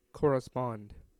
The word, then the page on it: correspond: American English; IPA : [ˌkɔɹ.ə.ˈspɒnd]